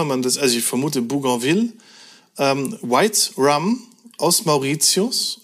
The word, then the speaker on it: Bougainville: Wie spricht man es aus? Deswegen habe ich es so ausgesprochen, weil es ist benannt nach Louis Antoine de Bougainville.